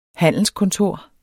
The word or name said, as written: handelskontor